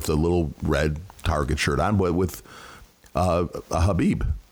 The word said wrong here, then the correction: hijab: I think you mean hijab. – That's not how you pronounce it at all, Glenn. It's called a hijab.